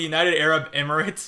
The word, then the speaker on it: United Arab Emirates: I just want to say this entire paragraph on the Puss in Boots Wikipedia page about how the United Arab Emirates, I mean, I know I mispronounced that. – No, that's exactly how it's pronounced.